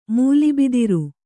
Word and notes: ಮೂಲಿಬಿದಿರು: mūli bidiru

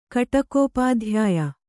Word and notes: ಕಟಕೋಪಾಧ್ಯಾಯ: kannada

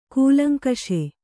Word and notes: ಕೂಲಂಕಷ: kūlaŋkaṣa

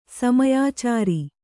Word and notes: ಸಮಯಾಚಾರಿ: samayācāri

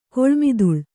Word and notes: ಕೊಳ್ಮಿದುಳ್: kannada

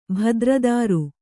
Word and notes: ಭದ್ರದಾರು: kannada

